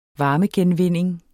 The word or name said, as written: varmegenvinding